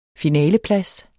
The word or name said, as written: finaleplads